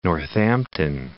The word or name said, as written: Northampton